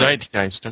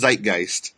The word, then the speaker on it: Zeitgeist: Zeitgeist. Okay, that's how you pronounce it. Okay. – Yeah, I don't know if it's the proper context for that, but it's pronounced Zeitgeist.